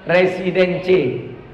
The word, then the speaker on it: Residence: Menata kepedulian umat terhadap lingkungan Masjid Al-Ikhlas Batam Nirwana Residence. Residence. Tulisannya Residence, cara bacanya residen.